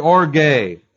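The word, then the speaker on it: orge (ὀργή): That's the Greek word orge, O-R-G, long E.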